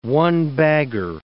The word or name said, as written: one-bagger